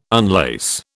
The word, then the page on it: unlace: British English